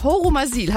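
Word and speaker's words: Horumersiel: Im schönen Örtchen Hormersiel. Habe ich das richtig ausgesprochen, Christian? – Ja, das ist absolut korrekt.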